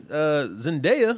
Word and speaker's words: Zendaya: And then Zendaya, is that how you pronounce her name? – Mm-hmm.